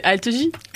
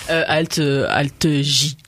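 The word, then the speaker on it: alt-J: Non, c'est Algie. – Algie. – Bah oui, bah comment je le prononce ? – Bah non, mais c'est comme Armanet. – Ah oui, pardon. Alt J.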